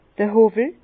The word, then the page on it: d'r Howwel: Bas-Rhin; Herrlisheim